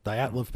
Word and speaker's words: Dyatlov: So the Dyatlov, how would you pronounce that? – Dyatlov pass.